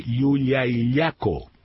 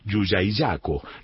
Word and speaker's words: Llullaillaco: En realidad, tengo que forzar la pronunciación, porque si yo leo normalmente, tengo que decir Llullaillaco. No se me ocurre decirlo porque me da la sensación de que originalmente debe ser Llullaillaco.